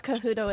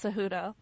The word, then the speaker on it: Cejudo: It's not Cejudo, it's Cejudo.